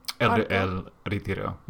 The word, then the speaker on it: Retiro: Parque del Retiro? Is that how you say it? – El Retiro, yeah. – El Retiro. – We just say, like, El Retiro, okay.